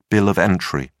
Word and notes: bill of entry: British English

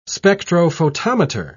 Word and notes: spectrophotometer: British English

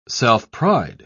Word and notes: self-pride: British English

